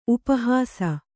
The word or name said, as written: ಉಪಹಾಸಿ